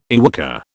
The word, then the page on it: YWCA: British English